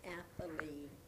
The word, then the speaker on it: Athalie: Yes, my name is Athalie, that is spelled A-T-H-A-L-I-E, the last name, Range, R-A-N-G-E.